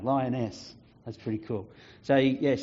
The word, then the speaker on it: Lyeines: It came written L-Y-E-I-N-E-S, and I didn't know how to say it. And so when I rang up and was talking to Baptist World Aid, I said that, and they said, oh, you're the one that got Lioness.